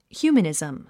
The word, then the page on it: humanism: h júːmənìzm; ヒューマニズム